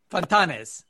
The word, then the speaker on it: Fontanes: Give it to me one more time. Fontanes. I had the accent on the wrong syllable.